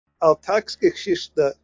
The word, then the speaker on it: Alltagsgeschichte: del Altgeschichte, o como se diga? – Pero Alltagsgeschichte, creo que se pronuncia más o menos así, Sí.